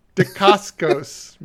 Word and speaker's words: Dacascos: It stars the chairman from Iron Chef America, Mark Dacos. Well, Daca. wow, I can't say his name. Holy keep, shit! Keep trying, Mark. Mark. – Correct him. – Dacascos. Mark Dacascos.